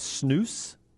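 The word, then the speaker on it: snus: Chris says it's pronounced snooze. – All right. – That's the way they say it over there.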